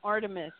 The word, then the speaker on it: Artemis: Yeah, like the Greek name, Artemis, A-R-T-E-M-I-S.